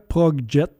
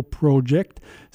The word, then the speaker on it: Arca Progjet: Alors, ce que vous venez d'entendre, c'est un nouveau groupe italien du nom de Arca Progjet. J'ai bien dit Progjet et non pas Project. Ça s'écrit P-R-O-G-J-E-T.